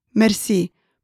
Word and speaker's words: merci: Another simple and common way of saying thank you is the French word merci, pronounced in a Romanian way. Let's break that down. Merci. Roll the R almost like in Spanish. Once more.